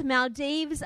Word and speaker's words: Maldives: My daughter got married 3 weeks ago, yesterday, and just got back from the Maldives. Maldives, apparently, is how you say it, this morning at 6 o'clock.